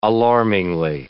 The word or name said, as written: alarmingly